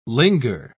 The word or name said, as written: linger